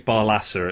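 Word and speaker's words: Barlaser: Daniel, I think it's Barlasser, it's pronounced, the midfielder, is their best player, I think.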